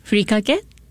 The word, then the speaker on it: furikake: The furikake, is that how you pronounce it? – Yes, furikake, the dry seasoning.